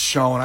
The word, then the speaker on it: Chão: I said Fogo de Chão for years. All of a sudden, they said, no, it's you're saying it wrong. – Yeah, Lauren was the one that was like, it's shown, actually.